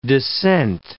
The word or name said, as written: dissent